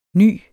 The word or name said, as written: nys